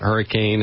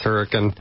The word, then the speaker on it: Hurricane: That's how we pronounce it down there. It's not Hurricane, it's Hurricane.